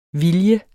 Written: VILJER

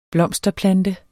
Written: blomsterplante

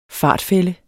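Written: FARTFÆLDEN

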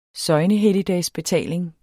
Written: søgnehelligdagsbetaling

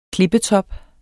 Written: klippetop

